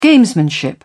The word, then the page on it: gamesmanship: British English